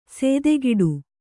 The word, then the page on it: ಸೇದೆಗಿಡು: sēdegiḍu